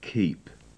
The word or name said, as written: keep